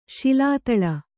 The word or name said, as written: ಶಿಲಾತಳ